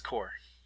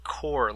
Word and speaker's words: Blast Corps: No, it's Core. Blast Corps. – Did it have a different name in different regions? – No, it's pronounced Core, like Marine Corps.